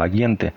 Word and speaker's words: агенты: Вайншток был убежден, что какие-то люди, которых он с таинственной лаконичностью и со зловещим ударением на первом слоге называл агенты, постоянно за ним следят.